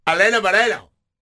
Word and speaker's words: Helena Moreno: And Helena Moreno is the president of the New Orleans City Council. She, look, I don't want to sound like a gringo. Let me say that right. Helena Moreno.